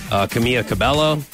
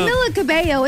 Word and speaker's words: Camila Cabello: Camila Cabello, it's like you pronounce the L's in her first name, but then you don't pronounce them in her last name.